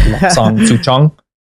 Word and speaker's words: Lapsang Souchong: So like I think Chase pronounced it Lapsang Souchong, but then I looked it up on YouTube and like the dude who was pronouncing it correctly, so I think it's like Lapsang Souchong.